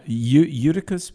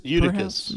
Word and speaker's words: Eutychus: And in a window sat a certain young man named, I've never been able to pronounce that. Uh, uh, Eutychus? – Eutychus, perhaps? who was sinking into a deep sleep.